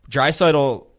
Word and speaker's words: Draisaitl: And Draisaitl, how do you say his name? – You got it. – Draisaitl.